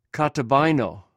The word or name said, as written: kataba